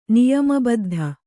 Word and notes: ನಿಯಮಬದ್ಧ: niyama baddha

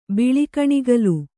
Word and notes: ಬಿಳಿಕಣಗಲು: kannada

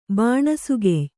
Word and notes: ಬಾಣಸುಗೆಯ್: Kannada

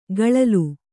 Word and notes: ಗಳಲು: gaḷalu

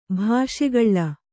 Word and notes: ಭಾಷೆಗಳ್ಳ: bhāṣegaḷḷa

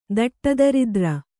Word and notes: ದಟ್ಟದರಿದ್ರ: daṭṭa daridra

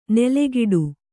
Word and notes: ನೆಲೆಗಿಡು: nelegiḍu; Kannada

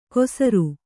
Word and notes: ಕೊಸರು: kosaru

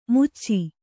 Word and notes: ಮಚ್ಚು: kannada

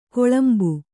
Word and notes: ಗಳಿಂಬ: gaḷimba

